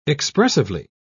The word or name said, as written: expressively